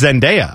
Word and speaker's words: Zendaya: Although Dua Lipa's fine, but the Zedediah? – It's... I may be saying it. I think it's Zendaya. I think I've been saying it wrong.